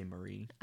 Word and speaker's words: Marie: I think they say Marie. – All right. – I put it as Marie in the synopsis. – All right. It's spelled like it is in, it's spelled in French, M-A-R-I-E, Marie.